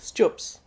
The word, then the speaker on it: stjups: And I also want to ask how you pronounce that marvellous word. – Stjups. – Yes, how Stjups. Do you say it? – Stjups.